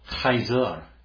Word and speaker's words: Caesar: In the Latin, they would have said, "Caesar," because it's a hard sound, not a soft.